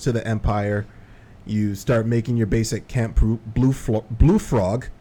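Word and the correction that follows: Bluefog: Blue fog. – Oh, sorry. That's another thing that everybody mispronounces. – Yeah, I thought it was blue frog. I'm sorry. – It's blue fog. It's camp blue fog.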